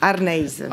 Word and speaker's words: Arneis: Yes, Arnais.